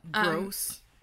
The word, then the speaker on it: Grose: Gross. – Yeah, gross, but spelled like G-R-O-S-E, so not gross. Gross.